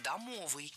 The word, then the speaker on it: домовый: Чаще я слышу вариант с ударением на окончании, но он неверный. Литературная норма только домовый.